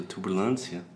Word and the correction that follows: turbulência: Turbulência. – Turbulência.